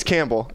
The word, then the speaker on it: Campbell: You say C-A-M and then, like, pull. – Yeah. – But no. – It's Campbell.